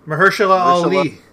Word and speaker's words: Mahershala Ali: And it's starring Marisha. – Mahershala Ali.